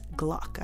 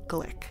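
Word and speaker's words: Glück: And just to clear this up before I go any further, you might think of her as Louise Gluck. I know I did, because her name is spelled G-L-U-C-K. But it's a Hungarian name, and it's actually pronounced Glick.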